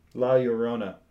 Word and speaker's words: La Llorona: La Llorona. Yes, there you go. – La Llorona.